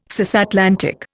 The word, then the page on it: cisatlantic: Indian